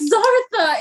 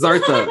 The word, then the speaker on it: Zartha: It's Zartha. It's Zartha. I don't remember. Okay, look, it doesn't matter. – It does. – It's an alien thing. – It rhymes with Martha.